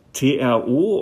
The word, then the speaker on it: TRO: Managing Partner bei der Sonic Branding Agentur TRO oder TRO. Wie spricht man das eigentlich aus? – Hallo, das spricht man Tro aus.